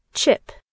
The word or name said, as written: chip